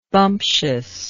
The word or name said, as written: bumptiousness